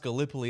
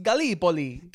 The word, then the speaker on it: Gallipoli: Like, bro, it's not pronounced Gallipoli. It's pronounced Gallipoli.